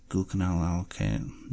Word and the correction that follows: Gluconolactone: That was a bad pronunciation. Let's try this again. Gluconolactone, sodium benzoate, calcium gluconate, sodium citrate, limonin, linalool.